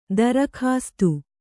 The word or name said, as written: ದರ್ಕಾಸ್ತ್